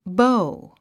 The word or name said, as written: beau